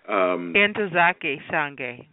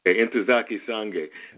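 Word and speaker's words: Ntozake Shange: I mean, from it was Colored Girls by, and I want to pronounce the name right, Nakatsuke Shang. Correct me if I'm wrong. – Entozake Shange. – Entozake Shange.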